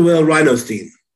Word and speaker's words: Will Rhinostein: Dr. Will Rhinostein.